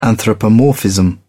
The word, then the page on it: anthropomorphism: British English